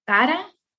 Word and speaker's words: Cara: It's Desiree Esteva Cara. – Can you spell that, please? – Oh, yes, of course. It's D-E-D-E-S-I-R-E-E Esteva E-S-T-E-V-A Cara, C-A-R-A.